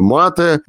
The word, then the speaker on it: мате: Вот, кстати, хороший вопрос, что популярнее, вино в Аргентине или тот напиток, о котором мы будем говорить, и это мате. Во-первых, правильно ли я произношу ударение? – Мате, да.